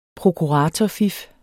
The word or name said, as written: prokuratorfif